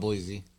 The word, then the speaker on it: Boise: I still call it Boise. Fuck y'all. – Is it? Well, you're saying it right.